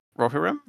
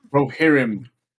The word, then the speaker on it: Rohirrim: Rohirrim? – Rohirrim.